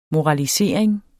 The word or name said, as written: MORALISERINGERNES